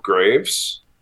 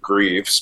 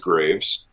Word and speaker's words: Greaves: My name is Daniel Graves. My half of my family pronounces it Greaves, but it is Graves, spelled Greaves, though.